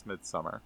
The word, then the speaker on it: Midsommar: He says Midsommar, so we're going to go with that.